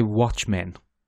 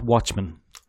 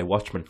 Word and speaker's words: Watchmen: I say Watchmen. Like, two words, not Watchmen. – I don't say that. I say Watchmen.